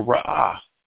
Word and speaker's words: Ra'ah: Jehovah Ra'ah. That's spelled R-A-A-H. R-A-A-H. Jehovah Ra'ah.